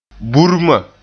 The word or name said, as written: burma